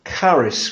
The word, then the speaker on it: charis: It's a word called charis, which is spelled C-H-A-R-I-S.